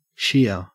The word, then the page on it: sheer: Australian English